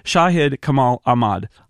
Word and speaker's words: Shahid Kamal Ahmad: I'm going to say his name and try to say it in a way that he's given me permission to mispronounce it. It's Shahid Kamal Ahmad. Hi. – How awesome. That was wonderful. – Okay, good. Good. Good. I tried. It's not quite right, but it's as close as my American mouth is probably going to get there.